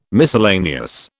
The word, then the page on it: miscellaneous: British English